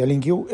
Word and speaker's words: LINQ: Se pronuncia LinQ, L-I-N-Q.